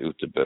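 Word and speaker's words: Göteborg: And I, I um, we basically went between um, Stockholm and Gothenburg, which is Gothenburg, but we always used to call it Uterberg because that's the way they pronounce it.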